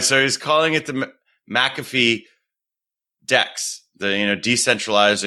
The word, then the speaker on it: McAfee: John McAfee.